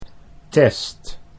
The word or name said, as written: test